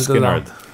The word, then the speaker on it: Skynyrd: Skynyrd.